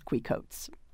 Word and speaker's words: Quixote: His other dog is a Pomeranian known as Quicoats, but spelled like Quixote.